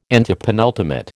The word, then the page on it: antepenultimate: American English